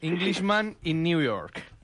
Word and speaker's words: Englishman in New York: Englishman in New York.